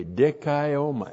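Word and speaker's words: dikaioma (δικαίωμα): D-I-K-A-I long O-M-A, dikaioma.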